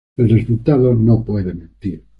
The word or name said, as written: mentir